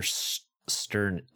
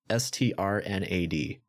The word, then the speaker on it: Strnad: The singer, who I'm completely about to butcher his name because I'm pretty sure, I think he has probably one of the hardest last names to pronounce ever. But the singer, Trevor Sternad? Trevor, it's Strnad.